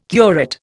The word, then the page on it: curate: Indian